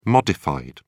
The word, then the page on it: modified: British English